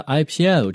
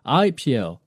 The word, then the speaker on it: IPO: 大家稍微注意一下，像 IPO 这样的一个词呢，我们的重读是放在后面的，不要读成了 IPO，而是 IPO on March the 18th。